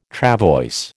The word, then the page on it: travois: American English